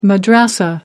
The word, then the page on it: madrasa: American English